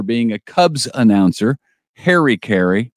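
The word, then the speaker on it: Caray: We know Chip Carey, pronounced Carey, will be the new television play-by-play announcer for the St. Louis Cardinals, broadcasts on Bally Sports this year.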